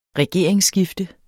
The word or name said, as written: regeringsskifte